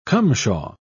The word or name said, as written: cumshaw